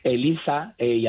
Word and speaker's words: ELIZA: ELIZA, y además está bien pronunciado con Z, aunque yo viva en Cádiz,